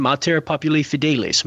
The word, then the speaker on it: Mater Populi Fidelis: The recent document, and you did right, you said it right, John. Good job. Kudos to you, man. Mater Populi Fidelis, Mother of the Faithful People of God.